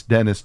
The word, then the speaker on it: Denis: His name is spelled D-E-N-I-S. – Okay. – I believe that should be pronounced Denis. – Denis. – Because you don't say penis.